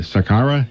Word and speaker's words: Saqqara: The headline is Untouched 4,400-year-old tomb discovered at, tell me if I'm pronouncing this right, John Kroc, Saqqara, Egypt? – Yes, that's correct.